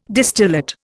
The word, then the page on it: distillate: Indian